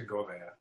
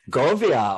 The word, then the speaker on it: Govea: Oh, it's actually Govea. – Govea.